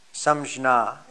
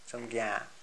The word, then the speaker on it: Samjna: And then perceptions, that is sense perceptions, which are called in Sanskrit, Samjna, S-A-M-J-N-A, Samjna, Samgyan, with a long A.